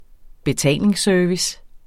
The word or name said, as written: betalingsservice